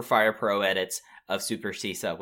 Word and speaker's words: Shisa: It's a weird thing, because in the ancient Ryukyuan like language, like, it's spelt Shisa, but it's also like the way that it's been said and like phonetically spelt throughout it's turned out to be a lot more similar to, as you said, like Roman Caesar.